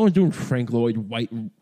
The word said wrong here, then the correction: Frank Lloyd Wright: Frank Lloyd Wright, Frank Lloyd Wright. That's how we say his name.